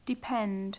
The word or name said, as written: depend